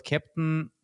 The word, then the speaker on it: Keptn: So what Kepton, as you said, it's an open source project. It's spelled K-E-P-T-N. So it's the German phonetic for a captain, because it's, you know, Kubernetes based.